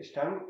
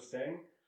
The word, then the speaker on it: Stang: Hi, I'm Michael Stang. In English, you would pronounce it Michael Stang.